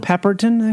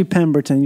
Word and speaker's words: Pemberton: Pepperton, actually? – Sorry. Johnny Pepperton is here. – I have cum all over my face. – That's how I feel now that I said it wrong. – It's actually Pemberton. You were right.